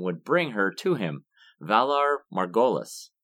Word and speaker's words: Valar Morghulis: I think it's Valar Morghulis. Okay. – Valar Morghulis. – Valar Morghulis. – That'll do it, yeah.